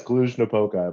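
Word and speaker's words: Cluj-Napoca: Klujnapoca? – Very good. Yes, Klujnapoca, I believe.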